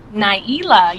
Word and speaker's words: Ni'Ela: Nyila, yes. – I need to pronounce the E a little harder, but you are the very first Nyila I've ever met in my life.